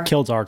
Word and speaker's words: Kildare: Kildare Club. – I'm saying it wrong, probably. Kildare. Someone that's Irish. K-I-L-D-A-R-E.